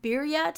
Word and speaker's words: birria: And I got back from them that the birria tacos, is that how you pronounce it? – Yeah.